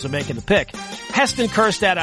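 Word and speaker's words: Kjerstad: But there's a silent J in Kerstad.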